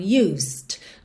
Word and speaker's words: used: O E não é pronunciado, OK? Absolutamente, você não fala nada desse E. Então used. Do S você já vai pro D.